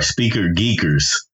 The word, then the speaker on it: Speaker Geekers: With making Speaker Geeker podcast, man. Speaker Geekers podcast. You gotta pronounce the S. It's plural. Because it's two of them, because.